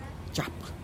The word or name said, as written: xap'h